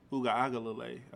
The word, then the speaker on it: Uiagalelei: And no knock on DJ Ugalele Uiagalelei. I think I said it wrong the first time. I got it right.